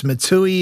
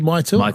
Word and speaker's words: Maitua: It was Matui, it was Matua. You said Maitua. – Maitua.